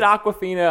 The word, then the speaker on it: Awkwafina: Is it Awkwafina? I feel like we're just naming her as water. – No, that's, yeah. – No, that's actually how you say it.